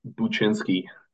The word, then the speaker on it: Buczynski: My last name is pronounced Buchinsky.